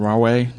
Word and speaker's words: Rahway: I have one from Jennifer from Rahway, New Jersey.